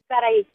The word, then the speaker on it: Sarai: Sarai, yes.